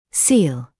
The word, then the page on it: seal: [siːl]; [сиːл]